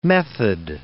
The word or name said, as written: method